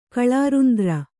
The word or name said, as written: ಕಳಾರುಂದ್ರ